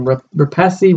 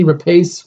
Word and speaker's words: Rapace: How do you say her name? Rapace? Rapace? – I've heard Rapace.